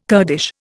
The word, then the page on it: Kurdish: Indian